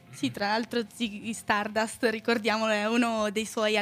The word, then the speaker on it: Stardust: soprattutto lo dice anche lui stesso rispetto a Ziggy Dardust, Stardust, scusate, continuo a pronunciarlo male,